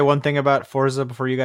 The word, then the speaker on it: Forza: It's pronounced Forza. – I don't give a crap. Forza.